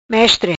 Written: Mestre

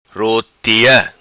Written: Rutia

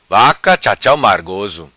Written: Vaca Chachau Margoso

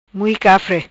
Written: Mui Cafre

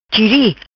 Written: Chiri

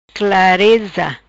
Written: Clareza

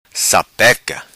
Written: Sapeca

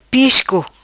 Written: Pisco

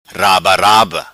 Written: rabaraba

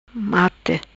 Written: Mate

